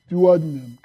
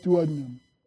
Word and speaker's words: duodenum: It's called the duodenum. Can you say that? Duodenum. Or you can pronounce it duodenum. Yeah. So you'll hear it pronounced both ways, duodenum or duodenum.